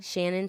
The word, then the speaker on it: Shannon: Shannon Siona. – Thank you. S-H-A-N-N-O-N-S-I-O-N-N-A.